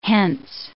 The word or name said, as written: hence